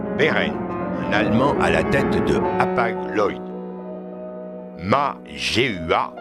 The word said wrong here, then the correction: Ma Zhenhua: je le prononce bien, j'espère, Ma Zhenhua, un Chinois à la tête de Costco.